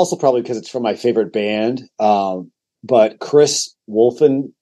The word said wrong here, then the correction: Chris Wolstenholme: uh, I gotta say it right. Uh, Chris Wolstenholme, home, I think from Muse.